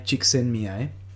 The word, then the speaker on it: Csikszentmihalyi: And one of the proponents of this concept of flow was Mihai Csikszentmihalyi.